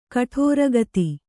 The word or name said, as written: ಕಠೋರಗತಿ